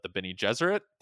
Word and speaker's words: Bene Gesserit: Bene Gesserit.